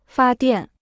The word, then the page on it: 发电: fā diàn